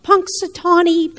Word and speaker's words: Punxsutawney: Punxsutawney, Pennsylvania.